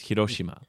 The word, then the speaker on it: Hiroshima: Yeah, but then you hear Japanese people say it, it's Hiroshima.